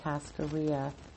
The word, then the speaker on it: cascarilla: Anyway, she said it's probably one of the oldest, oldest, oldest hoodoo practices, powdered egg powder or cascarilla. Um, cascarilla is spelled C A S C A R I L L A. Cascarilla, cascarilla, powdered eggshells.